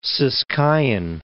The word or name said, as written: Ciskeian